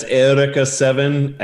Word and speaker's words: Eureka 7: Is there a consensus? – Yeah, it's Ithaca 7 and Gurren Lagann.